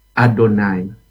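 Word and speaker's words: Adonai (אֲדֹנָי): Jadi kalau kita lihat bahasa Ibrani-nya itu ditulis YHWH, tapi bacanya Adonai.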